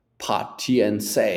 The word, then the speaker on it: patience: Right, so here the T is pronounced sh, and the A is not pa, it's not patient, patience, pa, patience. – No, no, pay, sh, pay, like a, a, pay, patience, patience. I am patient with my students.